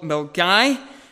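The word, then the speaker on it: Milngavie: It's in a little place called Milgai. It's pronounced slightly different than what it's spelt there.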